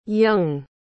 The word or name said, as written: jung